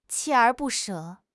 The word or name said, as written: 锲而不舍